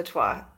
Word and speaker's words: Twa: And now correct me if I'm pronouncing this wrong, the Twa? – Right, Ovatwa, the Twa.